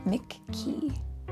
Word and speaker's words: McKee: Oh, yes, but Mickey Mouse is spelled M-I-C-K-E-Y, and this guy's last name is spelled M-C-K-E-Y. E-E, McKee. It's a little bit different. McKee. Yeah, not Mickey. McKee.